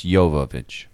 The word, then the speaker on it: Jovovich: By the way, it's nobody pronounced Jovovich. It's Slavic. It's Jovovich.